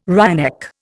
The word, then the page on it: wryneck: Indian